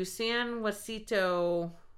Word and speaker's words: San Jacinto: So it said, drive to San Jacinto. I probably said that wrong. – That sounds good.